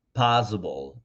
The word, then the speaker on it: possible: No possible, sino possible, possible. ¿Ok? Muy bien, con esa P bien explosiva, possible.